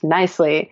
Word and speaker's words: Knisley: My last name is spelled K-N-I-S-L-E-Y, and it's pronounced nicely. The silent K really throws people off.